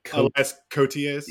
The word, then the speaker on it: Koteas: Elias Koteas.